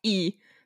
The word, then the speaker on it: Lee: The name Lee in Korean is pronounced E. Just E.